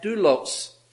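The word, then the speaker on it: doulos (δοῦλος): It is a Greek word that is, if we give the anglicized pronunciation of that, doulos.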